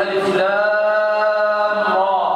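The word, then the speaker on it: الر: وقوله تعالى ألف لام را، هذه حروف مقطعة، تنطق هكذا ولا تنطق إلا كما أقرأ. ألف لام را، ولا بد من التلقي.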